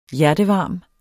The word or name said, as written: hjertevarm